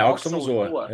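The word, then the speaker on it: Aoxomoxoa: Só que, pô, corrige minha pronúncia aí, que é o Aoxomozoa. – É, Oxomozoa. É isso mesmo, Oxomozoa.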